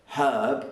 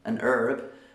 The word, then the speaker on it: herb: So in America, the word herb is pronounced with a silent H, and they say an herb. Whereas me as a Brit, I say a herb. And so because I say the H, in Britain we say a herb, whereas an American would say an herb.